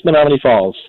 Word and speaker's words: Menomonee Falls: Why don't we go from, I don't want to mispronounce this, from Wisconsin, Menomonee Falls. Did I get that right? – Yes, Menomonee Falls.